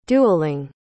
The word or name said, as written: dueling